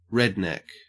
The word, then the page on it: redneck: Australian English